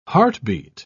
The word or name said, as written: heartbeat